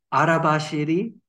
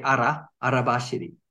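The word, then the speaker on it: arabashiri (荒走り): Now, sometimes it's pronounced because we spell it in Roman letters on the bottle. So sometimes people say arabashiri. – Yeah. – And so on. So don't worry too much about the pronunciation. Here in Japan, we say arabashiri.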